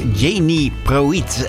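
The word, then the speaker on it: Jeanne Pruett: En het mooie van het internet is natuurlijk ook dat ze erbij schrijven hoe je dat moet uitspreken. Want haar naam is eigenlijk Janie Proiet. En ja, zou je misschien niet zeggen als je de titel leest.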